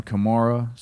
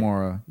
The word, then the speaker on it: Kamara: It's Alvin Kamara, so we can stop with the Alvin Kamara. So we've had it right. – Kamara, yeah. But you can get like an Alvin Kamara around that spot.